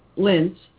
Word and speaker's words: Linz: Hey, you know, speaking of pronunciations, Linz is, Z in German is like an S. – S. – Yeah, and the S is like a Z.